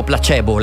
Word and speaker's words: placebo: No, ce ne sono altre che in realtà però noi pronunciamo correttamente, tipo placebo. Noi lo pronunciamo placebo. L'effetto placebo è il latino. – Però il gruppo musicale sono i placebo.